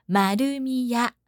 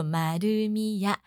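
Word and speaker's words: 丸美屋: 完美屋是那个丸是樱桃小丸子的丸，然后美丽的美哈。 Marumiya 完美屋念作 Marumiya 自販機 corner。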